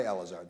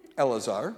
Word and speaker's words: Elazar: Rabbi Yochanan and Rabbi Elazar, that's how you pronounce it. Elazar.